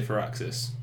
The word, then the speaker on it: Firaxis: I'd say Firaxis.